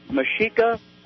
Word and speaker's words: Mexica: They're part of the Mexico Mexica, if you pronounce the X like an S-H.